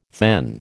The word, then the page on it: thegn: American English